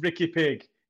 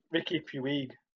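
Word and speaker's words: Riqui Puig: I don't think his name's pronounced Ricky Pig.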